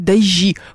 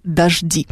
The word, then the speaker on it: дожди: Но при этом не надо говорить дожди, потому что во множественном числе они дожди.